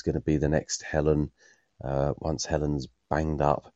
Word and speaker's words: Helen: One thing I wanted to say was we've got a daughter called Helen, and perhaps it's because we're down south, but we call her Helen as it's spelt. But in the Archers, it always grates on me when people, especially Pat and Tony, call her Helin, as if it's her name spelled H-E-L-I-N.